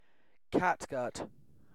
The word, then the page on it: catgut: British English